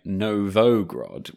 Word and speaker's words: Novgorod: And before we start this week, we should apologise for a slight mispronunciation of the word or town, Novgorod. I think we spent all of last episode saying Novgorod when it's in fact Novgorod.